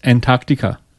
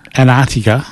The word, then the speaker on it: Antarctica: She sent us a song about how Americans mispronounce Antarctica. – How do they mispronounce it? – They don't pronounce the T's appropriately, apparently. – Antarctica. – Yeah. – Antarctica. – They obviously think the T is silent.